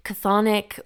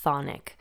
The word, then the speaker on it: chthonic: Chthonic or chthonic can be a bit of a tongue twister, and I've heard people drop that k or h sound at the beginning and simply say thonic.